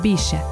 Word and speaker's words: Bixa: El nombre común del achiote se deriva del náhuatl achiotl, pero su nombre científico, Bixa Orellana, proviene del taíno Pixa, que los indígenas pronunciaban Bixa,